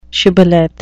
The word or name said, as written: shibboleth